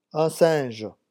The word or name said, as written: un singe